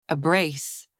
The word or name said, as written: a brace